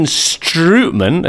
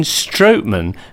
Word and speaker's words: Strootman: And Kevin Strootman, as I would describe him, the great player at Roma, is not that at all. It's Kevin Strootman.